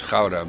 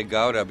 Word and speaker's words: Gouda: It's Gouda, but because I'm talking in English, I always say Gouda, because otherwise people don't understand what I'm talking about.